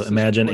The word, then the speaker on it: Imaginn: It's URL. It's imaginn.ai. So Imagine AI.